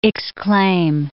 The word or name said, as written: exclaim